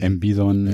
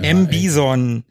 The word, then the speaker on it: M. Bison: M. Bison. – Ja, M. Bison. Natürlich. Mit ganz weichem S.